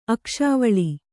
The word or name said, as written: ಅಕ್ಷಾವಳಿ